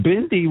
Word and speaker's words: Bindi: Yeah, Bindi rhymes with Mindy, and that's a Jew name.